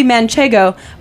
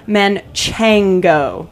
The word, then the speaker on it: Manchego: But in this case, there's always been a lot of confusion as to how the word is pronounced, what the word actually is. So the fourth cheese on our plate today is actually Manchego, but it's going by the name of Manchango.